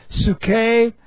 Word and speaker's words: psuche: The word is psuche. Looks like this in the Greek. P-S-U-C-H long E. Psuche.